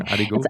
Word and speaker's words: aligot: Aligot. – It's aligot. – Aligot. Okay. And this is something like basically it's mashed potatoes with mixed with fondue, basically.